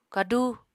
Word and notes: kadú: apatani

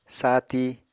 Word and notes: saati: Apatani

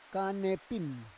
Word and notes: kanepin: Roon

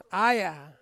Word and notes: aya: Ansus

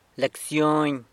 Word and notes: lecsyony: sanlucasquiavini

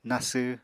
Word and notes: nasw: Apatani

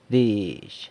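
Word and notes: dizh: San Lucas Quiaviní Zapotec

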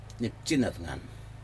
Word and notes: nipjen atnag: Aneityum

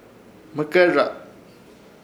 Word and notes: Hmɨkerraz: Ishir; Chamacoco

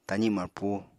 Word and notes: tazi marpu: apatani

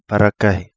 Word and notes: Parakahi: Kapingamarangi

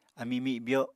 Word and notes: ami mibyo: Apatani

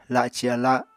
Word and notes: laci ala: Apatani

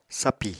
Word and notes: sapì: apatani

